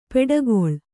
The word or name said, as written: ಪಡುಕುಳಿ